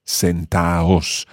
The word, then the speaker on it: sentaos: sentaos. So you don't pronounce the D.